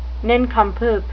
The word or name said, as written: nincompoop